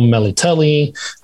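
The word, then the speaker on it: Melitele: Geralt takes her to the Temple of Meletala, or if you go by their pronunciation of the show, Meliteli. I'm not going to say that. I learned it a different way, so I'm going to stick with mine.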